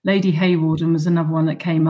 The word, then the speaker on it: Hawarden: Clementina, I think her first name is Hawarden.